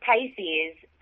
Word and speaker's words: Pysis: So it's Pisces, pronounced like the water sign, Pisces, but spelled P-Y-S-I-S for protect your shoes in style.